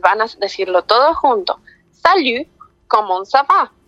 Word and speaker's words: salut: Entonces, hola es salut. Como cuando le dicen a, cuando alguna persona estornuda y ustedes le dicen salud, vamos a tratar de hacer ese sonido, pero al final vamos a cerrar la boca y pronunciar como si fuera una U y una I unida. Entonces sería salut.